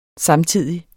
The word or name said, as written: samtidig